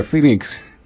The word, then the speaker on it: Phoenix: Phoenix.